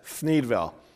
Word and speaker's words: Thneedville: It's not my lisp, that's how it's said, Thneedville.